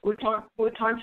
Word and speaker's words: Wuhan: But the other thing people need to know is it happened in Wuhan. Is that the correct pronunciation? – Yes. – Wuhan, China, because it was known as an extremely polluted place.